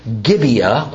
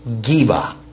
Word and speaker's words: Gibeah: In this case, what we in English typically say as Gibeah, and it's usually how it's written in our Bibles, Gibeah, is actually pronounced Geba.